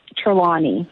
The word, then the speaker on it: Trelawney: Trelawney, yeah.